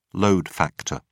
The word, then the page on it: load factor: British English